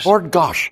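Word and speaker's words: Bord Gáis: Bordgosh. – Yeah, look at you. I don't even need to teach you how to pronounce Bordgosh Energy Theatre. Perfection, as I suspected.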